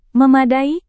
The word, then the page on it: memadai: Indonesian